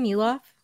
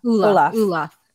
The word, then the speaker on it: Ulof: And Ulof, is that how you say his name? Ulof? – Yeah. – Ulof. Sounds like Olaf.